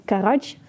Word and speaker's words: Karaj: Dat is De Prijs van Vrijheid en De Amerikaan van Karaj. Zeg ik dat goed zo? – Ja, zeker.